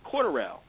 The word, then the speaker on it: Cordelle: B-L-I-D-I-W-R-E-H. Bleedy Ray. And then we have a young fella named Corderell Patterson. C-O-R-D-E-L-L-E. Corderell. Corderell Patterson. And there was a name I couldn't even pronounce. It's M-E-N as in Nancy, E-L-I-K.